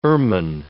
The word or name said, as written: ermine